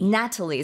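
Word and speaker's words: nattily: Natalie. So it's N-A-T-T-I-L-Y. – But it sounds like the name Natalie. – N-A-T-A-L-I-E. – Right, like Natalie Portman.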